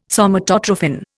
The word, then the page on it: Somatotrophin: Indian